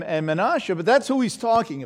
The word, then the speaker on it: Manasseh: Because the tribe of Joseph was divided up between his two sons, Ephraim and Manasseh, which is how you pronounce those names. Ephraim and Manasseh, these two sons.